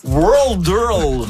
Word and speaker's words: Worldle: Audrey-Anne, le jeu World Earl. – Oui, c'est dur à prononcer. W-O-R-L-D-L-E, comme le world, comme le monde.